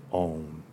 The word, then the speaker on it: Om: And as HBB points out, it could be a three-syllabled word, a two-syllable, it can be A-U-M. Remember, it's study. Imagine, it's like the three worlds, right? Om, you're ending with the dental. You're starting in the back. So air, is going through the palate, becoming a oo. And then the dental, the clip, but it's a nasal M, so it's vibrating constantly.